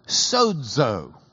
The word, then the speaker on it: sozo: Luke uses S, long O, Z, long O, sozo.